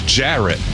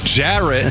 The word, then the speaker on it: Jarrett: Ferret does rhyme with Jarrett, but my name is Jarrett. Jarrett.